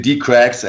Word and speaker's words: DeeCRACKS: So actually it's wrong when somebody says the DeeCRACKS because that would mean the cracks, right? – Right. – Yeah. So it's actually DeeCRACKS, actually it's just German.